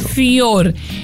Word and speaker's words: Fjord: Quien ganó la Palma de Oro ahora en el recién finalizado el 24 de mayo, Cannes, el festival, fue Fjord, porque se escribe F-J-O-R-D. – Fjord. – Ajá, como Fiordaliza, pero exacto, sin el Daliza. – Exacto. – Fior.